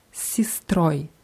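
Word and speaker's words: Sistroi (с сестрой): Sistra, Sistroi. Padruga. – Spadrugoi. – Spadrugai. Ja, wenn die Endung oi betont ist, wie bei sistroi, wird, wird es auch deutlich wie o ausgesprochen.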